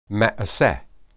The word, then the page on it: maeseh: hebrew